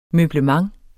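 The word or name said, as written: møblement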